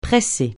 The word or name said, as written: presser